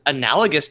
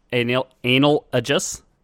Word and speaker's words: analogous: They had demigods, so Hercules, I guess, would is analogous to an angel, right? – Yeah. – Is he sort of? – I believe it's pronounced analogous. – Analogous? – Analogous.